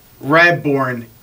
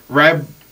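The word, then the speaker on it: Radbourn: I mean, Old Hoss Radborn. Burn, I believe that's how you say it.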